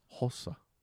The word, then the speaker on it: Hossa: But I had a chance to interview him, and I said, how do you pronounce that name? And it was this, I could have gone either way on it, the way he did it. It was more like, it's Hossa.